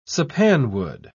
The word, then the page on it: sappanwood: British English